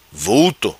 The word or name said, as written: Vulto